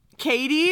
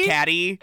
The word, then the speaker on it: Cady: Katie? – Caddy. – Oh, yeah. – It's going to be hard for me not to call her Caddy the whole time. – It's spelled C-A-D-Y.